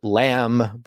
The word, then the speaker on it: lamb: But when you had endings on word, so lamb, but it would not have been pronounced as simply lamb. It might have been lambu or lamban or something at the end. So that B would have been pronounced before that suffix.